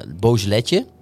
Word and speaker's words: Beaujolais: Wij hadden een lekker Beaujolaisje, een natuurwijn, een Beaujolais natuurwijn, Beaujolais, sorry, zeg ik het goed? – Zeker, aangeraden door onze wijngoeroe Cesar Majorana.